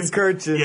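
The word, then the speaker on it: Kurkjian: Tim Kirkjian. Is that how you say it? – Tim Kirkjian.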